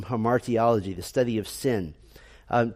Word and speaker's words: hamartiology: So it is not hamology, uh, it is not hamatiology, um, so the easiest way to remember is to put the emphasis on the second syllable, hamartiology.